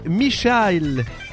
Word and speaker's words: Meechaile: Michael, comme Michel, mais M-E-E-C-H-A-I-L-E.